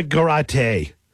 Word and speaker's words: Garate: Jessica Garate rhymes with karate.